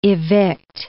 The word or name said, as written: evict